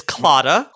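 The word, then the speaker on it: Claddagh: I'll pronounce it. It is clotta.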